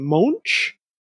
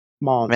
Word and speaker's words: Moench: I never know how to say his name. Monch? M-O-E-N-C-H. Monch. – Mench, maybe. – Minch? Monch? Whatever.